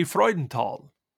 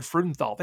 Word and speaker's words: Freudenthal: And actually in German, it would be Freudenthal. Would be the German pronunciation. Okay. When we were in Germany and we told them our name, how do you pronounce it? We said Frudenthal. They all laughed at us because we said it so poorly.